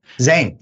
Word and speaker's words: Zayn: and I think it's Jane or One Direction. – Zane, is that who left? Zane. That's spelled Z-A-Y-N.